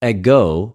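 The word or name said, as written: ἐγω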